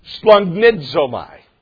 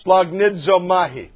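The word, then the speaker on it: Splagnidzomai (σπλαγχνίζομαι): Splagnidzomai. Splagnidzomahi. That's it. Say it with me phonetically. Splag. Let's all make you Greek scholars. Splag. That was easy, isn't it? Nidzomahi. Splagnidzomahi.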